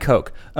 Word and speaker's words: Koch: K-O-C-H? Is that usually pronounced Cook? Cock? – Coach? – Coach? Coca-Cola? – It's going to be Coke. – Yeah, probably Coke.